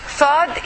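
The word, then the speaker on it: Saad (صاد): Saad is the same letter S like seen, but it is with a dot. What is the meaning with a dot? It means that you pronounce it, okay, in a tough way or in a heavy.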